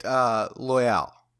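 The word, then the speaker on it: Loial: And how do you say his name? Loyal? – I say it Loyal. Just Loyal. Just think of it as a very fancy way of saying loyal.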